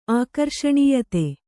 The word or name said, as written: ಆಕರ್ಷಣೀಯತೆ